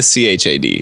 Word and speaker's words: Chad: I'm gonna my username is spelled OMGCHAD, but just say the CHAD.